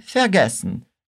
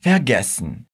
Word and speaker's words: vergessen: Se você prestar atenção, esse último verbo tem a sílaba fea no começo. No alemão, nós temos partículas separáveis e não separáveis. No caso, essa é uma partícula não separável. Por isso, a gente nunca vai colocar a tônica da palavra nela, sempre na sílaba que vem logo em seguida. Por isso nós falamos vergessen.